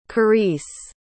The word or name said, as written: karise